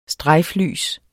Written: strejflys

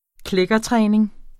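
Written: kliktræning

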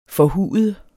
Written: forhud

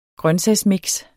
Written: grøntsagsmix

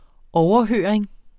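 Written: OVERHØRINGERNES

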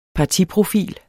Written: partiprofil

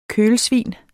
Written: kølsvin

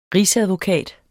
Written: rigsadvokat

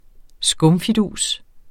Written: skumfidus

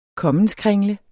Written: kommenskringle

